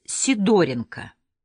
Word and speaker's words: Сидоренко: Петру Ивановичу Сидоренко, он сам ставил ударение на 2-м слоге, было 46 лет.